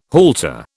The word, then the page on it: halter: British English